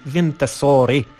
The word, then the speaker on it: Wintersorg: Nazwa tego zespołu pisze się Wintersorg, tak jak Göteborg. No ale wymawia się, wiadomo, Göteborg po szwedzku, czyli Wintersorg.